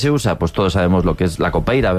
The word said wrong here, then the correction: capoeira: ¿verdad? – Capoeira. – Qué mal pronuncio últimamente. – Capoeira, ¿no? – Capoeira.